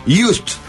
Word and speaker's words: JYSK: Vas a pirar en Just. – Todo. – Just. Es impresionante. – Repetí conmigo. – Just. Just. – Just. – Just. Pero se escribe J-Y-S-K, pero se pronuncia...